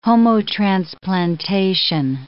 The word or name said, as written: homotransplantation